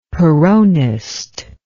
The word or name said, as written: peronist